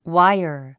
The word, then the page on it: wire: wáiə r; ワァイァ